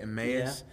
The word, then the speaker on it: Emmaus: The Emmaus Road, is that how you say it? Emmaus? – Yeah.